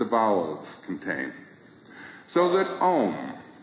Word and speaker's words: Om: Now this sacred syllable Om, which is repeated in all of the prayers of India, is composed of four sounds. One is Ah. Second is U, ow, and the third is M.